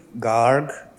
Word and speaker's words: Garg: My name is Narinder Garg. It is spelled N-A-R-I-N-D-S David E-R, last name G-A-R-G.